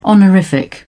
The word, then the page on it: honorific: British English